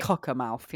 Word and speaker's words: Cockermouth: C O C K E R M O U T H. – Oh, straight up, just Cockermouth. Okay, yeah, Cockermouth.